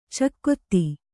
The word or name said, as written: ಚಕೋತ್ತು